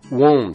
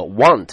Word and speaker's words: won't: 特别注意到这个 won't 啊，它是 will not 的缩写，那么你一定要注意到这个词的发音和 want 区分开来。